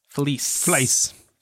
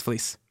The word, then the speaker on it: Fliess: Wilhelm Fleis, F-L-I-E-S-S. Fleis. It's Fleis.